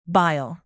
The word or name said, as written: bile